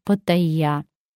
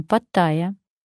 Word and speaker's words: Паттайя: А некоторые мои знакомые предпочитают замечательный курорт Таиланда, лучший город, который называется Паттайя, а не Паттайя. Это зафиксировано в словаре, и давайте будем грамотно отдыхать и выучим ударение.